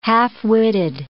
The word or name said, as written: half-witted